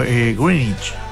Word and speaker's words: Greenwich: Como ellos nos dicen Greenwich. – ¿Cómo se dice? Le dicen como Greenwich.